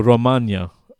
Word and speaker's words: Romagna: No, Romagna. Because I know that because the Emilia Romagna race in Formula One, the Grand Prix, is held at Imola, and they always say Romagna, which I think is a region, like a region in Italy.